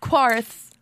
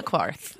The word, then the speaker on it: Qarth: Yeah. Not Qarth. – Not Qarth. – Not Qarth, but Qarth.